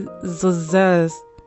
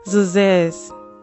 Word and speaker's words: zhuzh: And then today's word is, how you say Zazaz. Zuzez. Wait. Zuz. Alright, so that's spelled Z-H-U-Z-H.